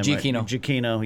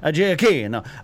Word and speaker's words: Giacchino: It's definitely more of Star Wars than Rogue One with Giacchino. I can never say his name. – Giacchino, yeah. Giacchino.